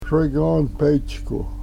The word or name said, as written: sregonpechku